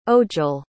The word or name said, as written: ojal